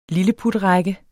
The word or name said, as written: lilleputrække